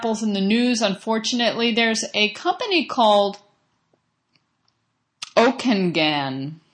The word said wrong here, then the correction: Okanagan: It's Okanagan. I'm missing a syllable there. Okanagan.